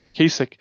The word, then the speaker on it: Kasich: I love that they call Kasich? How do you say his name? The Ohio Kasich. – Governor? Kasich.